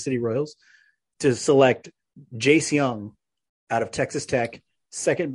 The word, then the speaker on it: Jung: It's spelled Jung. It's spelled like Jung, but I'm pretty sure it's pronounced Young.